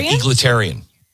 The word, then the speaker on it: egalitarian: How do you say this word? I sound like such a moron all the time. – Egalitarian. – Yes, egalitarian. – Thank you.